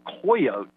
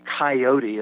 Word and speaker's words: coyote: Mark Twain is the one in Roughing It who told Americans that the correct pronunciation was coyote as a three-syllable. So we've ended up, as I said, in parts of the country with that as the primary pronunciation of the animal's name.